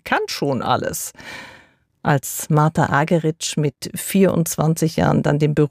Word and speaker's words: Argerich: Zum Orchester mit Charles Dutoit kommt jetzt Martha Argerich als Solistin dazu. Hierzulande wird sie oft eingedeutscht Argerich genannt, aber sie selbst spricht sich Argerich.